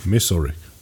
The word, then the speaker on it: Missouri: Missouri.